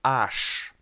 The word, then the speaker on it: аж: Слово аж, да, пишется оно а, две буквы, а и ж, аж. Но произносится как аж, а плюс ш.